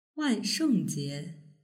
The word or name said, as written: wanshengjie